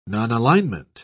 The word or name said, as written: nonalignment